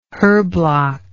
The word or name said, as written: herblock